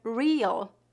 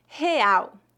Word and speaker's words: real: Real, real, real. Note que a letra R em início de palavras é pronunciada na garganta, mais ou menos como a letra H no inglês, real real.